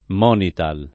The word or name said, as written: Monital